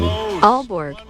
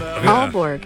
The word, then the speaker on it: Aalborg: Aalborg. – Yeah, there you go. – What? – It sounds so natural, just like that. Teacher in that classroom Adam was talking about. – Aalborg.